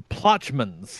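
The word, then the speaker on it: Plochman's: Is it Pitchman's? – The Plotchman's. – How do you spell it? – P-L-O-C-H-M-A-N apostrophe S.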